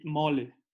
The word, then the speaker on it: mole: How do you say it again? – Mole. – Mole. – Mole. Yeah.